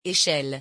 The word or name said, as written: echelle